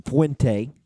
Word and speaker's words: Fuente: And Justin Fuente, however you say that, is going to be... – that is how you say it. You got it. – Justin Fuente. Fuente.